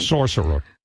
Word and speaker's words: Saoirse: Well, I did see Saoirse, whatever you say. How do you say that name? – Sorcerer Ronan. – Right.